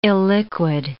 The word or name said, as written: illiquid